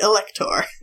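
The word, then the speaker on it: Électhor: You don't pronounce the letter H in French, so it'd be Electhor, which is not so impressive.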